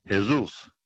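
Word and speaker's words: Jesús: Along with the letter J, Jesus in Mexico is pronounced Jesus with the H, yeah.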